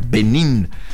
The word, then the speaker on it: Benín: Benín, acento en la I. Benín.